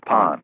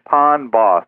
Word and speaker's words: Pond Boss: I couldn't let the pound boss slip by. – It's not pound. – It's pond. I know. – Yeah. Quit saying it wrong.